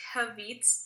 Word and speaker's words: caveat: So this is how you pronounce it. – Caveat.